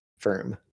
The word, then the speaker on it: Ferm: Alright, but it's spelled like F-E-R-M. Firm.